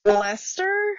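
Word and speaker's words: Leicester: So in Dane Hills near, I think it's pronounced Lester.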